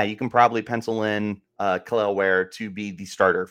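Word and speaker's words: Kel'el: I believe it's pronounced Kal-El, like Superman.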